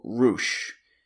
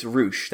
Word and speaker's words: Rush: But anyway, Ricardo, today we're going to be looking at the themes of one luchador in particular, El Toro Blanco Rush. Not Rush, not the Canadian rock band, not Leo Rush, not Courtney Rush, not Jeffrey Rush. It's Rush. That's how it's pronounced.